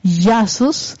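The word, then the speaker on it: Iasos: Así que con eso en mente, los dejo con estos dos compositores, pueden ir ya buscándolos, Yasos, así se pronuncia, pero se escribe esa I A S O S y Stephen Harper y entonces hagan ustedes sus propios viajes para ver qué les parece.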